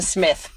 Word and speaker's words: Psmith: I can't help but just leave it to Smith. P. Smith. Yeah. Like P-S-M-I-T-H. – Yeah, that's a great one. – The P is silent.